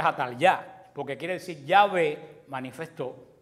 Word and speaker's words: Atalía: Atalía quiere decir Atalya, se pronuncia Atalya.